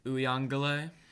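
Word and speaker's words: Uiagalelei: Uh, DJ, how do you say his last name? – Uyangale. – Uh, that's the official pronunciation I'm mm-hmm.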